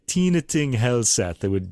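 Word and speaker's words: Tine Thing Helseth: Tina Ting Helseth is the pronunciation for this, or close to it anyway.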